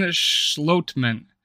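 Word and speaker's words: Schlottmann: Let's talk about Austin Schlotman. Apparently, it's how you pronounce it.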